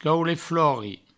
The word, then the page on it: caulet-flori: provençal